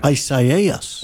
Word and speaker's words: Isaias: I don't know, but it's nice to say, and our Spanish friends say, Isaia. – There we go. – They don't pronounce the S.